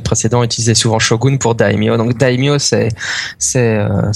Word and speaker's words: daimyo: donc ils prononcent daimyo, mais ça prononce euh, pas daimyo, c'est daimyo, hein. parce qu'il y a un A hein.